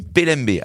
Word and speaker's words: Pelembe: Mais voilà, Skinny PLMB. Alors PLMB, ça s'écrit P E L E M B E tout simplement.